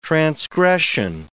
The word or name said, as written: transgression